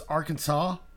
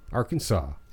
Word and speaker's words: Arkansas: Three syllables. – Yep. – Final S silent. – Yep. – So it's Arkansas. – Arkansas. – Yeah. A in each syllable with the Italian sound. – Like an Italian accent or some shit. I didn't really understand that part of it. – Yeah, I don't know. – Accent on the first and last syllable.